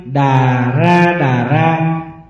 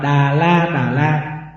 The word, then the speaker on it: Đà Ra Đà Ra: Xuất tượng thứ 30, Đà Ra Đà Ra, còn đọc là Đà La Đà La.